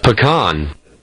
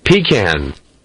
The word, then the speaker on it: pecan: They say in the United States, if you're above the Mason-Dixon line, people say pecan, but if you're in the South, it's pecan.